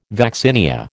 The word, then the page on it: vaccinia: American English